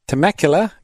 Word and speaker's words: Temecula: Listening from Temecula, California.